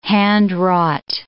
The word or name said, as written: handwrought